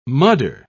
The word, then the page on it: mudder: British English